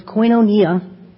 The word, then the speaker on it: koinonia: It's the Greek word that I pronounce koinonia, which means partnership.